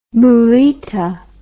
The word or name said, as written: mulita